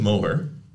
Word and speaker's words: Moher: So our first matchup is the Irish Cliffs of Moher.